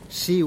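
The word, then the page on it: shiu': Teotitlán del Valle Zapotec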